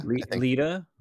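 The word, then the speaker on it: Leida: Is that how you pronounce it, Lydia? Leda, Leda, Leda.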